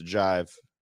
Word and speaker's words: JXVE: JXVE, but it's pronounced Jive.